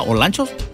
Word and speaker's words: Olancho: ¿Olanchos? – No, somos de Copán. – Oye, porque ahí ese lugar, ¿sí lo dije bien o lo dije mal, Olanchos? – No, Olancho, de donde, según dicen, entran muchos y salen pocos.